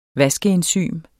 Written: vaskeenzym

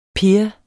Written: peer

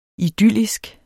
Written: idyllisk

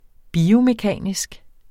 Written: dels .. dels ..

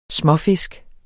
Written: småfisk

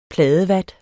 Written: pladevat

